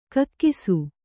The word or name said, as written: ಕಕ್ಕಸ್ಸು